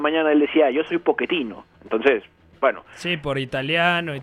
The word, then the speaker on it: Pochettino: Y, y también eso a nivel plantilla se siente y por ahí viene el fichaje de Mauricio Pochettino, porque me, Pochettino, mejor dicho, porque así lo pronuncian.